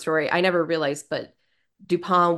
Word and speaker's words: Dupin: The most important literary contribution Edgar Allan Poe ever made was the character of Dupin, or the French pronunciation. I didn't take French. Dupin. – It's just like a Dupin.